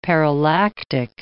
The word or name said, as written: parallactic